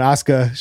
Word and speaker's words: Asuka: I'm gonna Asuka. suck you. – Uh, yeah, that one, Asuka.